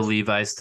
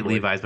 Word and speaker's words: Levis: Will Levi's to Green Bay? Is that the chant I'm hearing? – Possibly. – I say Levi's, but I'm pretty sure it's pronounced Levis.